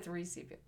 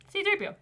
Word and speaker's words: C-3PO: I think I said 3CPO. Yeah, anyway. – C-3PO.